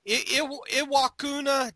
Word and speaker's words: Iwakuni: He's from Beaver Falls, but he now lives in Aqua. I'm going to say this wrong. You know I am. Iwakuni, Japan. – Iwakuni, Japan.